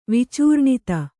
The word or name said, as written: ವಿಚೂರ್ಣಿತ